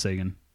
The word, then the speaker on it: Seguin: So just for everybody, Tyler Seguin or Seguin. – It's pronounced Seguin.